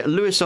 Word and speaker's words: Louis: So next up is going to be Louis Armstrong. I'm not sure exactly when we were told that his name should be pronounced Louis and not Louis, but there it is. We should pronounce it Louis.